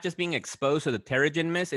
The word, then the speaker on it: Terrigen: And if you're exposed to something called the, I hope I'm saying this correctly, Terrigen Mist. – Terrigen. – Yeah, Terrigen Mist.